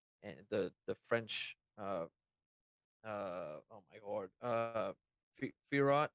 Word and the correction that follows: Fiorot: I can never say it. – Fiaho. – I can never. Thank you. – Manon Fiaho.